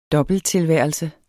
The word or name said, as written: dobbelttilværelse